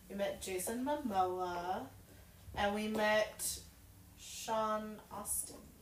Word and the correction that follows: Astin: Astin. Astin. I can pronounce things correctly.